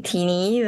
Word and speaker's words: Tini: Call me Tini.